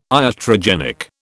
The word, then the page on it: iatrogenic: British English